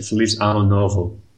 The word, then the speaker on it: Feliz Ano Novo: Feliz Novo Ano. – Isso. Sorry, Aaron just corrected me. Feliz Ano Novo.